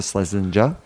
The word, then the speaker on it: Schlesinger: And Isla Islinger, is that how you say her name is Isla Slesinger. Schlesinger. – Oh, Schlesinger. – Schlesinger. – That's it.